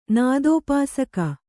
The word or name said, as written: ನಾದೋಪಾಸಕ